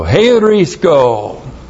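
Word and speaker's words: Heurisko (εὑρίσκω): There's the H. E-U-R-I-S-K, long O. Heurisko.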